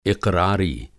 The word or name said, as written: इक़रारी